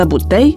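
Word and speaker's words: bouteille: De exemplu, deși se aseamănă cu pălărie, cuvântul butelie se accentuează diferit pentru că este un substantiv neologic și păstrează accentul din limba de origine franceză. În franceză se pronunță butei, iar în română butelie.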